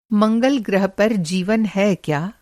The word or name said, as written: मंगल-ग्रह